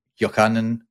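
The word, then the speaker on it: Yochanan (יוחנן): Yochanan is pronounced something like that in Hebrew.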